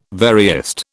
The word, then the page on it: veriest: British English